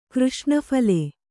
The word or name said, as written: ಕೃಷ್ಣಫಲೆ